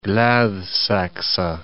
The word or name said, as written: Gladsakse